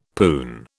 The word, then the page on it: poon: British English